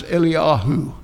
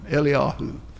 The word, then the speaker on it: Eliyahu: They call him in Hebrew, they don't pronounce, we pronounce it in English, Elijah. In Hebrew, they speak of Elijah as Eliyahu, Eliyahu.